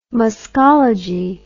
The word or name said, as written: muscology